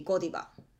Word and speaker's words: ゴディバ: But speaking of McDonald's and McDonald's, there's also some other uh, brands which are um, pronounced differently, like the chocolate Godiva in Japanese it w l be ゴディバなんかそのマクドナルドとマクドナルズと似ててやっぱ日本でゴディバ